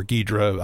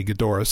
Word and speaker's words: Ghidorah: Well, their solution is what they consider their god, which is the monster that destroyed their planet, that being Ghidorah or Ghidra. I'll try and say Ghidorah since that's how they pronounce it in it.